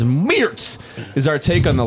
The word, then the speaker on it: Meerts: It's called Meertz from Funk Factory Gooseria. – How do you spell it? – M-E-E-R-T-S.